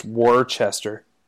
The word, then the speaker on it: Worcester: It's actually pronounced Worcester. Not Worcester.